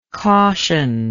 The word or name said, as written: cautioner